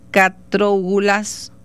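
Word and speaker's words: Katrougalos: George Catrougalos, así se pronuncia, exacto,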